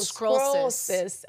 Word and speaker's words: sclerosis: Try to say it like scroll, like I'm reading a scroll. – Oh, sclerosis.